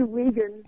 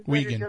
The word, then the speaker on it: Wiegand: Did I say your name right? Is it Wiegand? – It's actually Wiegand. Wiegand. It doesn't really matter. – Okay. I see W-E-I and I pronounce it like my own name, Weisberg.